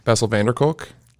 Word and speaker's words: Bessel van der Kolk: Hadn't heard of it until I read the wonderful book, The Body Keeps the Score by Bessel van der Kolk. Right? Did I say that right? – Yeah, you did.